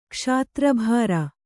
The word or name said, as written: ಕ್ಷಾತ್ರಭಾರ